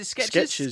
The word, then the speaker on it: Skechers: He's got his Skechers deal. He's good for life. – Don't start me on those. – I can never say it right. Is it Skechers? – Skechers.